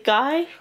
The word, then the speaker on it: Guy: Is it Guy? – I think it's Guy because he's not French. Guy is the French pronunciation.